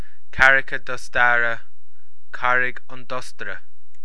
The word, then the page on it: Carraig an Dostaire: Irish